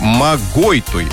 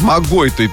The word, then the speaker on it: Магойтуй: Ударение на второй слог. Магойтуй. Вот, извините, вот так вот. Магойтуй.